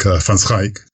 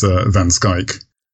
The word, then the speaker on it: van Schaik: Pronounced, by a Dutch person, they would probably tell me I'm wrong, but something like van Schaik, but we anglicize it as van Schaik.